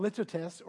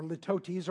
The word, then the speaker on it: litotes: It's called litotes or litotes or litotes. You can pronounce it pretty much any way you want to because any one of those pronunciations is okay.